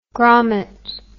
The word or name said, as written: gromet